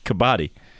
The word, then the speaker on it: Kabaddi: Kabaddi. – Kabaddi.